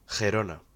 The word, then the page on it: Girona: Spanish